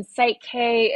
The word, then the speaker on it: Psych-K: So for anyone who's listening, Psych K is spelled PSYCH-K. So like kind of based on psychology, or your psyche is really where it comes from.